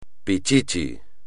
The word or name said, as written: pichichi